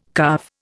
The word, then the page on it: calve: Indian